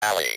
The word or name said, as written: valley